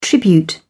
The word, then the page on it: tribute: British English